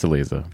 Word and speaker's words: Aliza: It's pronounced Aliza. Just ask Dory since it's Hebrew.